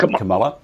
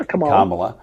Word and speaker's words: Kamala: I mean, I've said the name right, Kamala. – Oh, yeah, Kamala.